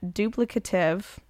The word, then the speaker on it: duplicative: So basically, McCarthy is calling the commission duplicative. Interesting. Did I say that right? – Yeah.